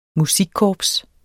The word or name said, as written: musikkorps